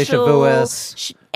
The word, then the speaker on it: Shavuos: So Ashkenazi pronunciation is the default in like families. – You still say Shabbos. You say Shabbos. You say Shavuos.